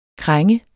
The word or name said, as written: krænge